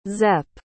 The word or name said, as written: zep